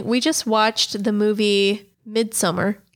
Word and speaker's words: Midsommar: I believe it's pronounced Midsommar. – Midsommar.